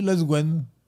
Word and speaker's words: Lesquen: Et, donc, donc ça devrait se prononcer Les Gwen.